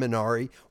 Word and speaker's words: Minari: Minari, yeah.